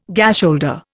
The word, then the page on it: gasholder: Indian